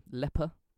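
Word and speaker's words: Ieper: Dutch or Belgian, I think it's leper.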